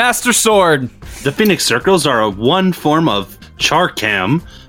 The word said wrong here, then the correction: chakram: A charcam is a round weapon, kind of like chakram. – Yeah, it's pronounced chakram.